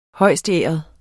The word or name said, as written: højstæret